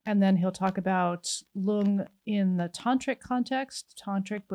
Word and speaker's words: rlung: So lung in a Tibetan transliteration is spelled R-L-U-N-G, but the R is silent, so we'll call that lung.